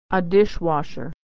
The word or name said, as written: washer